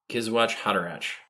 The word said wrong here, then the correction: Kwisatz Haderach: right? – Something like that. – Come on. – Kwisatz Haderach. – There you go.